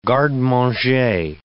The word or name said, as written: garde-manger